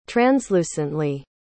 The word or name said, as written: translucently